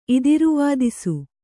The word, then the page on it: ಇದಿರುವಾದಿಸು: idiruvādisu